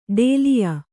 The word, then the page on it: ಡಾಲಿಯಾ: dāliya